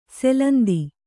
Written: ಸೆಲಂದಿ

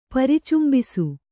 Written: ಪರಿಚುಂಬಿಸು

